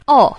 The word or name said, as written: o4